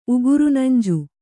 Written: ಉಗುರುನಂಜು

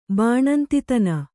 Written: ಬಾಣಂತಿತನ

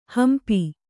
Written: ಹಂಪಿ

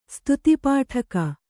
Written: ಸ್ತುತಿಪಾಠಕ

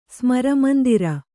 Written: ಸ್ಮರಮಂದಿರ

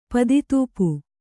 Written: ಪದಿತೂಪು

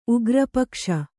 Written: ಉಗ್ರಪಕ್ಷ

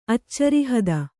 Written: ಅಚ್ಚರಿಹದ

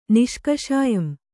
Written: ನಿಷ್ಕಷಾಯಂ

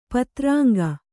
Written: ಪತ್ರಾಂಗ

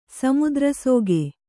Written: ಸಮುದ್ರಸೋಗೆ

